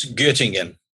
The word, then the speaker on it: Göttingen: Nobody can pronounce Göttingen. – But Göttingen.